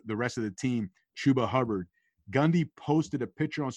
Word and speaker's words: Chuba Hubbard: Chuba Hubbard and Mike Gundy. – I was wondering how you were going to say his name. One B and then two Bs.